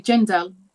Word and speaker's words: Jendal: Okay, Jendal. It's just pronouncing the J, really.